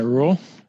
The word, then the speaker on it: Ruel: And correct me if I'm pronouncing your last name incorrectly. – No, that's exactly right. It's Matt Rule.